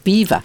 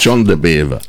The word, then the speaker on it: de Bever: Sounds like beaver. – No. It's the English, pronunciation, yes. John de Bever.